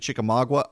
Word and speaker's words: Chickamauga: By the way, so I was talking to Scott Slade about Jeff Mullis from Chickamauga walking out so he didn't have to vote on this legislation. And someone sent me a nasty note saying I was mispronouncing it. I'm pronouncing it Chickamauga.